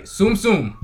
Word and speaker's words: Tsum Tsum: So going from that to the next one, which is Disney Tsum Tsum Festival. I have say it right. Tsum Tsum.